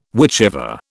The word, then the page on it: whichever: British English